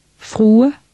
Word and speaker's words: Frue: In word 13, don't forget to pronounce the final E. – Frue.